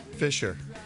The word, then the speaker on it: fissure: F-I-S-S-U-R-E. F-I-S-S-U-R-E. – Fisher. – Fisher. That's the way it's supposed to be pronounced. We're so redneck here, we always Z it up.